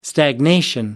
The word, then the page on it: stagnation: American English